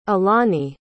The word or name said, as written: alani